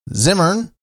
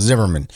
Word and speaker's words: Zimmern: Host Andrew Zimmer, Zimmern, is that how you say it? I always thought it was Zimmerman. Andrew Zimmern will take a tour of Cincinnati's culinary icons on the upcoming episode.